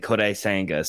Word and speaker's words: Kodai Senga: And by the way, can I just say you pronounced that amazingly, Kodai Senga. So props.